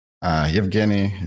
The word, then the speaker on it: Evgeny: Evgeny is the correct one. – All right, thank you. – That's fine. I'm Evgeny, co-founder of NutsGuru.